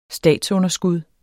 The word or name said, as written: statsunderskud